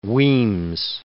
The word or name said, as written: Weems